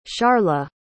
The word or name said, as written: sharla